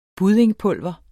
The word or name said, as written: buddingpulver